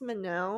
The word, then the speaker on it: Manon: And she's like, actually, it's Mino. And then we had to go into a whole conversation of how I think she grew up in France and is of French pronunciation.